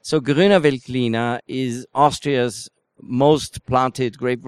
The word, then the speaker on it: Grüner Veltliner: Obviously, the big grape variety from Austria is Grüner Veltliner. Have I pronounced it right? – Perfect. You are really an expert already, you know.